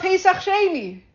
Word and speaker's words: Pesach Sheni: I'm talking about Pesach Sheni. – I'm listening. – Am I saying it right? – Yeah.